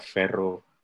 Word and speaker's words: ferro: Como por ejemplo, cuando te refieres a tu hija, por ejemplo, la H en el gallego se va, como, entonces, en vez de decir hija, dices filla, o hijo, dices fillo, o como hierro, se pronuncia ferro.